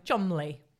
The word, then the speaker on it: Cholmondeley: Now, here on, I'm going to be calling him Chumley. – Cool. – Because as much as his name very much is not spelled Chumley, apparently that's how it's pronounced.